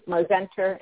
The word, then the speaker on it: Mozenter: And you can reach me on my Facebook at Bonnie Mosenter Albers. So I'll spell it for you. Bonnie is B-O-N-N-I-E. Mosenter is M-O-Z-E-N-T-E-R. And my last name, Albers, A-L-B-E-R-S.